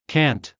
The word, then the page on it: cant: American English